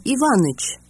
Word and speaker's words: Иванович: Pronunciation of the patronymic. – Отчество. Иванович. О Иваныч. Николаевич. О Николаевич. Петровна. Сергеевна. О Сергеевна.